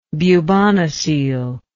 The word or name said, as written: bubonocele